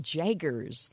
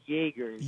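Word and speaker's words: jaeger: Yeah, well, it's pronounced Jaegers. – Jaegers. Okay, it's spelled with a J, but it's pronounced Jaegers. Yeah.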